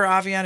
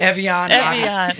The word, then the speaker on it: Evian: Do you remember Avion? Have you seen Avion? – Evian.